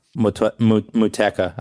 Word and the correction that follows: Motueka: I don't know how to pronounce it, but oh, hang on. Oh, Motueka. Yeah, there we go. – Yeah, Motueka. Yeah.